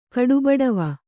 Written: ಕಡುಬಡವಿ

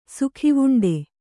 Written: ಸುಖಿವುಂಡೆ

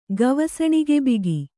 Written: ಗವಸಣಿಗೆಬಿಗಿ

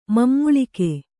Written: ಮಮ್ಮುಳಿಕೆ

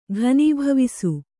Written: ಘನೀಭವಿಸು

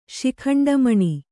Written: ಶಿಖಂಡಮಣಿ

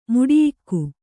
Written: ಮುಡಿಯಿಕ್ಕು

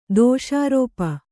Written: ದೋಷಾರೋಪ

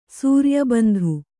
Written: ಸೂರ್ಯಬಂಧು

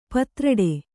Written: ಪತ್ರೊಡೆ

